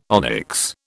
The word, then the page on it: onyx: British English